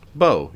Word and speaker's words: Bau: Is it Bo? B-A-U. – Bo, yeah.